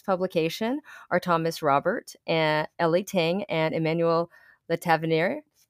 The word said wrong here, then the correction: Le Tavernier: I did not say that right. Le Tavernier, probably, because they have French, yeah. So Emmanuel Le Tavernier is probably correct.